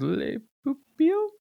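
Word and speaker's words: Le Pupille: And the guess was Le Pupil. Did I say that right? – Yeah. – I said it fast enough to not be wrong. – The pupil. – It helps if you don't go real high at the end.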